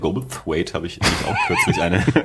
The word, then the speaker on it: Goldthwait: Ich habe ja in unsere, unseren Themenspeicher auch mal Bobcat Goldthwait Bobcat Goldthwaite. Dankeschön.